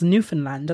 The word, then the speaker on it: Newfoundland: So they weren't pronouncing the found. They were really pronouncing the new and land. So it was Newfoundland. And I was like, oh, okay. And then I looked it up and I was like, oh, so they actually do. It is pronounced Newfoundland, there, not Newfoundland. – Not Newfoundland, just Newfoundland.